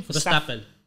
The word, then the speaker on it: Verstappen: So that means Verstappen. How's he say his name? – Verstappen. Verstappen.